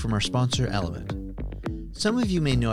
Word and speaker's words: LMNT: I ordered some Element, spelled with the four letters L-M-N-T, which has essentials like magnesium, potassium, and sodium, and over a 30-day period, I saw my headaches decrease by about half.